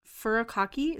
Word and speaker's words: furikake: Like furikake. Is that how you say it? – Yeah.